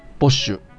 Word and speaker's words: Bosch: 皆さん、ボッシュってご存知ですかね多分、綴りね、見たらね、すぐわかると思います。うん、 BOSCH と書いて、ボッシュ。